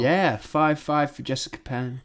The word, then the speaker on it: Penne: Yeah, Penne. – The official pronunciation.